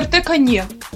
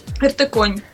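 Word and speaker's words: RTCon: В котором было написано, ну там РТ-кон будет, бла-бла-бла-бла-бла, ждем вас на РТ-коне. Ну там наверняка это было коне, но читалось как на РТ-коне. Собственно, да, оттуда и пошло РТ-конь. И никак иначе.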